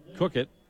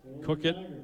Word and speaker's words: Coequyt: So that was number 34, Cookett. He gains about a yard on the play. Cookett, his name is spelled C-O-E-Q-U-Y-T.